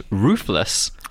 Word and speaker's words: Ruffles: But you can confirm that ruffles are still a thing. Pronounced roofless by the Spanish, they are a very popular brand.